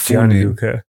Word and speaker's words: Gianluca: But it's Gianluca.